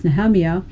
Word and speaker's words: Nehemiah: Here we have Nehemiah, or Nehemiah, but it's pronounced Nehemiah,